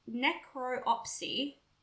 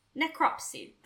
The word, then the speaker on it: necropsy: Well, you'll be happy to know that the I have to repronounce this. It's necropsy. – There we go. Yeah. – Was still able to be performed. Necropsy. That sounds better. – Yeah. I think it's necropsy. It's also like Flopsy, the elephant that got killed.